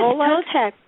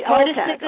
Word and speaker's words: Toltec: Did I say that right? – Yes, Toltec. Right. – Toltec.